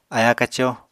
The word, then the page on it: aya-kaco: apatani